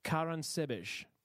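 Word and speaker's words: Caransebeș: And they set up camp surrounding the town of Karansebeş. Ooh, I've said that correctly. – Yeah? – Yeah, I checked it. I checked it on the article.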